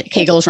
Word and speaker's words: Kegels: One of the things that I definitely had misconceptions about when I started PT was Kegels, which also I didn't know how to pronounce, but informed Kegels rhymes with bagels, which has been very helpful.